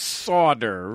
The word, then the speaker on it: solder: And the word solder is pronounced sawder, right? – Man.